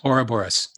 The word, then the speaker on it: Ouroboros: And there's one symbol, it's called I don't even know how to say it properly, Ouroboros. – Ouroboros, snake eating tail.